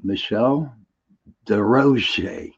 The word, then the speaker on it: Michelle Desrochers: Without further ado, I would like you to introduce you to my guest, Michelle Desrochers.